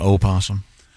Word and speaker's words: opossum: Is the O always silent in possum? – Yeah, I think so. – And you don't call it an opossum? – No. – I think you can. It's not silent if you pronounce the O. – Opossum? – But I think either is correct.